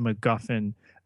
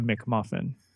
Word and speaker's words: MacGuffin: And Matt, I'm so glad that you properly pronounced an object used to advance the plot, a MacGuffin. That other podcast you listen to would have called it a McMuffin.